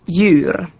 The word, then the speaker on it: djur: Konsonanttiyhdistelmissä DJ, GJ, HJ ja LJ jäävät D, G, H ja L ääntämättä. – DJ esimerkiksi djur, eläin.